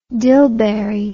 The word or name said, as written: dillberry